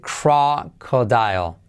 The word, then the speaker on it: crocodile: Eh, es parecido, pero se dice crocodile. Cra, acuérdate de una rana cuando croa. Cro, cro, que, crocodile. Dial. Con una pizquita de L al final no es dial, sino dial.